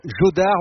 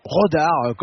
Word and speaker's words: Jodar: le jeune Raphaël Jodar, ou Rodar comme vous voulez, si vous le prononcez à l'espagnol ou à la française,